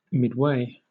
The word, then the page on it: midway: British English